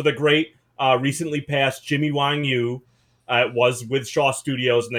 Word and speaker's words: Wang Yu: but he's Wong Yui, UA? And apparently, so anyways, the point here, my horrible mispronunciation aside, was that one of the ways that his name will be pronounced is Wang Yu.